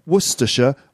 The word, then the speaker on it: Worcestershire: So Worcestershire looks like Worcestershire. It's actually pronounced Worcestershire or even Worcester in some cases.